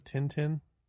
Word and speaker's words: Tintin: And that's exactly where, you know, I think Asterisk and Dintin, or Dun Dun, as I've heard some people pronouncing it now. I can't remember. What can you think of? – You're talking about Tintin? – Yes.